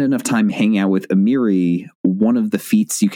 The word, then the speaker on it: Amiri: Also, I've never heard it pronounced, so I'm guessing there. – Yeah, no, you got it right. Amiri.